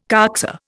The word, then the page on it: coxa: Indian